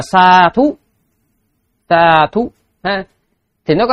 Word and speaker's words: sādhu: Sa thú. Đấy, cái từ nó là như vậy đó, sa thú. DH chứ không phải là TH.